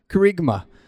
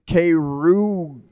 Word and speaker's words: kerygma: Kerygma. It's a cool-sounding word, so I decided it would be really good. But if you were to pronounce that in English, it's kerugia. Kerugia. Kerugia. But it's kerygma.